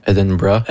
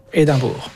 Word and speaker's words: Edinburgh: comment on le dit en anglais? – Edinburgh. – Edinburgh. – Edinburgh. Édimbourg.